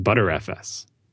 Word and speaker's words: Btrfs: Maybe that's all I could get out of his Twitter handle or email or something, was the first to point out that BTRFS is pronounced ButterFS. I had heard that pronunciation, but I wasn't aware it was the official. Apparently, it is the official pronunciation.